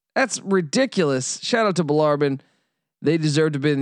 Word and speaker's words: Bellarmine: Jacksonville, my Dolphins, got their hearts broken in Louisville, Kentucky against Bellarmine. I said it right this time, damn it. Bellarmine.